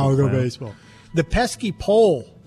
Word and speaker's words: Pesky: It's P-E-S-K-Y. And that's Pesky, not Pesci.